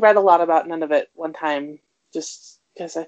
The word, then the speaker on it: Nunavut: But I guess it's more correctly pronounced Nunavut, but it doesn't, it didn't work.